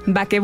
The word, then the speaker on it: Baqué: Entonces es Baqué con acento en la E. – Okay. – Y boulangerie, que significa panadería en francés. – En francés. – Entonces, Baqué boulangerie.